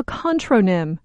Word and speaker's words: contronym: A word with opposing meanings is called a contronym, or contronym, spelled with an A.